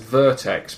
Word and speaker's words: Vertx: I presume it's supposed to be pronounced Vertex, but it's just capital V-E-R-T-X.